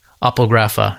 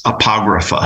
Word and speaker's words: apographa: apographa. How do you say it? – I'm not sure about the pronunciation. I usually pronounce it as apographa.